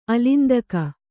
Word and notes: ಅಲಿಂದಕ: alindaka

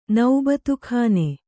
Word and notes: ನೌಬತುಖಾನೆ: naubatu khāne

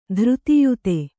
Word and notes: ಧೃತಿಯುತ: kannada

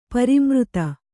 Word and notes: ಪರಿಮೃತ: pari mřte

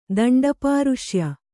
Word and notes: ದಂಡಪಾರುಷ್ಯ: danḍa pāruṣya

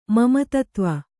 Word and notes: ಮಮತತ್ವ: Kannada

